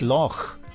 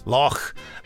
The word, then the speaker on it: Loch: The correct pronunciation is Loch. Och, Loch, as the Doctor and Mel say in Dragonfire.